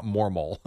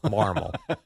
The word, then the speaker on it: Marmol: Not Mormol. – Marmol.